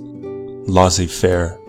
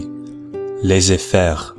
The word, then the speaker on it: laissez faire: If you noticed, I pronounced laissez faire the English way, laissez faire. I'm not an expert in French, although it is a language that I'm currently learning, but the French pronunciation would be laissez faire, laissez faire. In English, we would pronounce it as laissez faire.